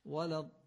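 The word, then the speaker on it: ولا الضالين: البته تأکید و تکلف زیادم نباید داشته باشیم، یعنی روی نون مکس زیادم نکنیم که شبیه تشدید بشه، یعنی نگیم وللضالین، این غلطه. این نون آخرشو نباید تأکید کنیم، با فشار و سختی بگیم، تلفظ بکنیم ولی معمولی به این صورت وللضالین.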